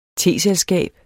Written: teselskab

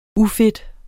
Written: ufed

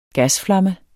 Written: gasflamme

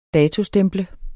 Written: DATOSTEMPLET

